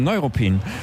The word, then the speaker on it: Neuruppin: Zurück in die Altstadt und ich habe Glück, der Schornsteinfegermeister, fällt mir vors Mikro und auch er sagt, Neuruppin wird eben nicht auf der letzten Silbe betont. – Nein, auf der ersten, Neuruppin, weil es gibt Altruppin und es gibt Neuruppin, eigentlich ist das ganz logisch.